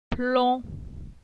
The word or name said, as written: Plon